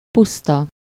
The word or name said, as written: mere